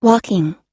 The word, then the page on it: walking: American English